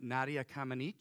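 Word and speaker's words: Nadia Comăneci: You and I have, we have been astounded by, I'm going to get her name wrong, but Nadia Comaneci. Did I get it right? Yeah.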